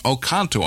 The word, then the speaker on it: Oconto: And you can write to Mark at 3852 North Oconto, I believe it's pronounced, O-C-O-N-T-O, in Chicago, Illinois.